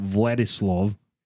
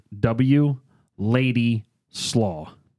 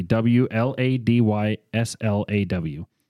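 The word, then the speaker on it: Władysław: I think it's called Vladislav, but it's pronounced W Lady Slaw. Literally W L A D Y S L A W, W Lady Slaw.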